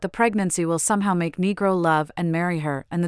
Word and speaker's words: Negro: In Argentina, El Negro, pronounced with a short E, can be a friendly way to refer to a man with darker hair or skin.